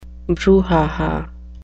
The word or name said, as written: brouhaha